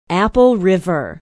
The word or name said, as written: apple river